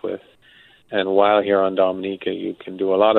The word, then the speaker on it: Dominica: Well, for those who have never heard of Dominica, first of all, it's pronounced Dominica, not Dominica. So we'll get that cleared up.